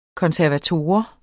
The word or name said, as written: konservator